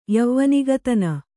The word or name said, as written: ಯವ್ವನಿಗತನ